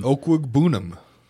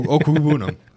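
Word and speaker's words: Okwuegbunam: OKWEGBUNAM. – Okwugbunam. – Perfect. You did it great.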